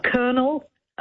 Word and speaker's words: colonel: Colonel. From French, you know, that even though it's pronounced K-E-I-N-E-L, it's spelled colonel.